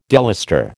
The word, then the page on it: delustre: American English